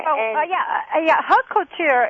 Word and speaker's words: haute couture: And what is, I'll probably pronounce this wrong, and I'll have the French woman here, the haute de couture, I think, and ready to wear. – Haute couture is designer wear.